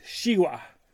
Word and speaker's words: zhigua: X is for zhigua, or pronounced shiwa, and is a Chinese and African name for watermelon.